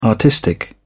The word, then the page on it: artistic: British English